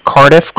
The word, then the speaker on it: Cardiff: Can you let him know that from the Bank of Cardiff called? And that's spelled C-A-R-D-I-F-F, as in Frank Frank. And my direct line... – A-R-D-I-F-F? – Yeah, it's like car and then different put together.